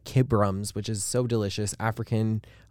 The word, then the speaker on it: Kibroms: K I B R O M S.